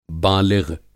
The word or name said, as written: बालिग़